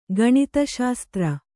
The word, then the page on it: ಗಣಿತಶಾಸ್ತ್ರ: gaṇita śastra